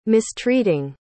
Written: mistreating

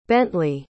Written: Bentley

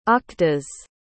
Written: aqdas

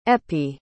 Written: eppie

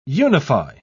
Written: unify

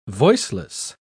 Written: voiceless

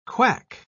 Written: quack

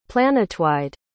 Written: planetwide

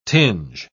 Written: tinge

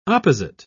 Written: opposite

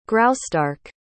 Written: graustark

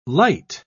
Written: lite

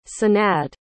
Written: sanad

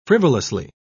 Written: frivolously

